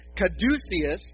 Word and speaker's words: Caduceus: The symbol to your right, which I'm going to pronounce this slowly, it's Caduceus.